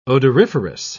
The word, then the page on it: odoriferous: British English